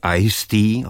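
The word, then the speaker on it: Eigsti: Ne, vystupovala dvakrát v pražském klubu Jazz Dog, poprvé jako host kvartetu pianisty a svého častého spolupracovníka jménem Taylor Ice-T, opravdu se čte tak jako ledový čaj, on se píše tak zvláštně, to teď nebudu rozvádět.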